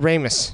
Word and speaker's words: Ramis: Isn't it Ramis with a long A? – I've always heard Ramis.